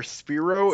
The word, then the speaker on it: Spyro: I always pronounced it Spiro, so I know. I know I'm wrong, fine.